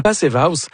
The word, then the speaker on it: Passive House: La Passive House, il faut prononcer avec un accent allemand, je vais dire.